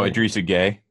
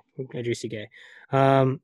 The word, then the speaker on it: Idrissa Gueye: Oh, Idrissa Gueye. – Idrissa Gueye.